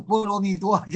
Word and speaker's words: 波罗蜜多: 就是波罗蜜多，就这个，人家这红字打得好，巴拉蜜达是这就是过去过去这个念法，现在就波罗蜜多就这样念了。